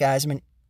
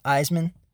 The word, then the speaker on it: Eisman: Because if I had a better name to reach people with, Eisman is just E-I-S-M-A-N. It's hard to, when you're interpreting it through audio, it's hard to know how to spell it exactly. Eyes, E-I-S, that's the hard part.